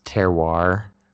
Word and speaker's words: terroir: It's terroir is how you pronounce it. It's T-E-R-R-O-I-R. And basically